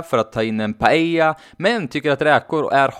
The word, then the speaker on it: paella: Och den här överprisade risgrytan stavas ju P-A-E-L-L-A. Och med tanke på det vi precis har lärt oss om dubbel L så uttalas det alltså paella och inte paella.